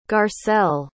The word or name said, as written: garcelle